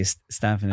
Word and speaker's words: Stefan: So they're like, obviously you don't watch the show. You don't know how to pronounce the lead character's name. – Oh no. – Can we do it again? And can you say Stefan? – Oh my God.